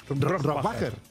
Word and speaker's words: Rohrbacher: Bueno, ¿cómo se pronuncia Alba Rohrbacher-Kochet? – Rohrbacher. – Rohrbacher. – Sí.